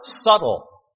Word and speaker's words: subtle: Not only are they seductive, but they are subtle. Subtle, doesn't sound like it's spelled. S-U-B-T-L-E. S-U-B-T-L-E, subtle.